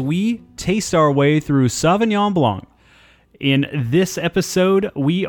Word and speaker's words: Sauvignon: I would just like to do one correction on pronunciation. It's Sauvignon.